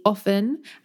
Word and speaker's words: often: What I found is technically they say that British English, we don't pronounce the T, so it should be often, and it's more of an US pronunciation to pronounce the T.